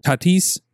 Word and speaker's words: Tatis: We don't know how to pronounce Titties. – That's why I like him. – Titties Jr. I can't even find him. – Fernando Tatis Jr. That's how you say his name.